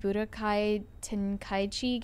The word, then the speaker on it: Budokai Tenkaichi: Who's hyped for the new DBZ Budokai Tenkaichi game? – That's correct pronunciation.